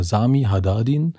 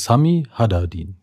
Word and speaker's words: Haddadin: Also ich kriege ja alle möglichen Aussprachen ähm, von äh, Sami zu Hadadin. Das machen die Amerikaner immer ganz gerne. Da heiße ich mal Sami Hadadin. Ähm, aber in der Tat heißt es wirklich Sami Hadadin.